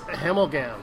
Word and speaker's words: Hemmelgam: And some of the guests she's going to have tonight is singer-songwriter Jeff, and I can pronounce this, Hemelgam, H-E-M-M-E-L-G-A-M.